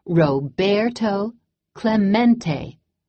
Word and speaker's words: Roberto Clemente: Roberto Clemente was his name, and this is pronounced Roberto Clemente.